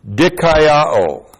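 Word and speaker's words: Dikaiō (δικαιόω): It comes from the verb dikaiō.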